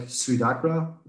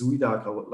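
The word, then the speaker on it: Suidakra: If we're talking in English about the band, I pronounce like Suadakra, suicide and dark. – Yeah. – But in Germany, we just use the German pronunciation, which is Suadakra, like with Z, you know, Suadakra, because the S is pronounced a little bit different in German. It's like Suadakra.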